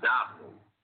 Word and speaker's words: Domhnall: But anyway, Donald, the Scottish Donald, D-O-F-N-A-L-D, from Gainesville, D-O-M-H-N-A-L-L. – Right. – Yeah, thanks, you know, pronounced Donald.